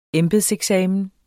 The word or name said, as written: embedseksamen